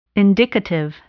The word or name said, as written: indicative